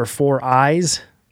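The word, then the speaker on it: 4iiii: Some other brands that you can look into are 4Eyes. That's how you say it, and it's literally spelled out 4Eyes.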